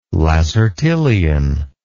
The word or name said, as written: lacertilian